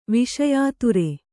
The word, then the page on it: ವಿಷಯಾತುರೆ: viṣayāture